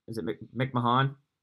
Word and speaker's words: McMahon: Tyler McQuinn from the Reds, and then Ryan is it McMahon? I'm probably gonna butcher that as well. – Yep, that's it.